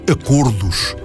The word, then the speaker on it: acordos: A palavra acordos pronuncia-se, sim, com O fechado.